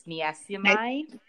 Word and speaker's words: niacinamide: So I got some more vitamin C and niacinamide. How do I say this? – Niacinamide.